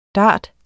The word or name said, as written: dart